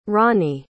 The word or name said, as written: ronny